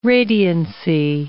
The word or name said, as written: radiancy